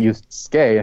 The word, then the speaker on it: Yusuke: Like, I was just impressed that he pronounced the first name correctly. – Oh, Yusuke? – Like, Yusuke, and he didn't do it as a Suke or Suk.